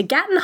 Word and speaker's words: Gatton: That is how you pronounce it. Gatton.